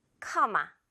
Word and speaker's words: comma: 영어 발음은 일단 코 발음도 아니고 어 발음이에요. 커. 그리고 M 물방울 하나만 있죠. 커마.